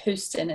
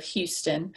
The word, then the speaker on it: Houston: It was Dr. James Houston, and I think it's pronounced Houston instead of Houston.